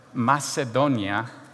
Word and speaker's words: Macedonia: But this word, Macedonia, the emphasis is on the do, okay, is a Latin Slavic word.